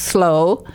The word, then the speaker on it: Slough: Slough, what we would pronounce Slough, is actually pronounced slow.